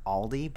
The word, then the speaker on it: Aldi: Again, like I said, even though I'm giving out the information, I am probably definitely wrong, and it's probably Aldi, but I definitely say Aldi. – Aldi. – Like yeah. – a long A, right? – Yeah.